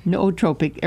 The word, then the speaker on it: nootropic: And, you know, here's another big report, medical report, that nootropic, is that pronounced? N-O-O.